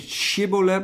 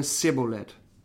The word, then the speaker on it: Shibboleth: die konden niet Shibboleth, maar die zeiden Sibboleth.